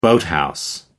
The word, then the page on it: boathouse: American English